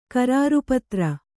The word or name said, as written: ಕರಾರುಪತ್ರ